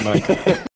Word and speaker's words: Nikon: Everyone else is pronouncing it wrong, for the record. It's Nikon.